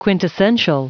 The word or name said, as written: quintessential